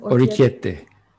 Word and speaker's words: Orecchiette: Orecchiette, is that how you say it? – Orecchiette.